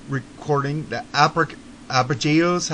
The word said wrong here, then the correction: arpeggios: How do you say that word? You've heard it say it. – Arpeggios. What is it again? – Arpeggios. I've heard this word before, and I'm not saying it right.